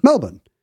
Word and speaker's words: Melbourne: I got in trouble the other day for not saying, for pronouncing the R in Melbourne, Australia, because people there say Melbourne. – But that's because they don't say the R anywhere. Australian English is not a rhotic dialect.